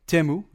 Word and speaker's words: Temu: How do you pronounce it? Temu. Probably. – I say Temu. – Temu, yes.